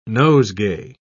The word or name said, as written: nosegay